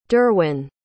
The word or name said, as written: derwyn